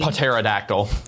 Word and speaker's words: pterodactyl: The guy that named pterodactyl is worse than both astronomers and crypto analysts. Why the silent P? – Pterodactyl.